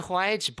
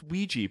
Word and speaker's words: Ouija: Oh, that's a Huaiji board. – It's a what? – That's a Huaiji board. – Oh, no. Did the servants tell you how to pronounce this? – Ja. – It's pronounced Ouija, baby.